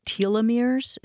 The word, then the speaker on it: telomeres: Let's turn to telomeres. Is that how I pronounce it? – That's a good way to pronounce it.